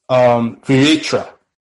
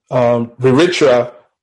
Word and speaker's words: Vritra: In the Vedas, you had the word Viritra, which is spelled V-R-I-T-R-A, which literally means the enveloper, which was a dragon or the personification of the adversary of Indra.